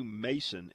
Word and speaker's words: Macon: You know, it's interesting that they pronounce that WMA, Bayou Mason, and it's spelled M-A-C-O-N. Now, I would think if it's B-A-C-O-N, what is that? Bacon, right? Why is this not Macon, Bayou Macon, and it's Bayou Mason? The other one is what is B-O-E-U-F, which down south, that's pronounced buff.